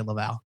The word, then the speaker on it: LaValle: This is written by Victor LaValle or Lavai? LaValle. I'm going to say LaValle with art by Joe Me Young.